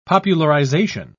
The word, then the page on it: popularisation: British English